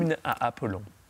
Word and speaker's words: Hymne à Apollon: Gabriel Fauré, Hymne à Apollon. Habe ich es richtig ausgesprochen? – Hymne à Apollon. – Ja, vor einem Millionenpublikum. – Wir denken, ja.